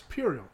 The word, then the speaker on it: puerile: they pronounce it puerile from how it's spelled, but it's puerile.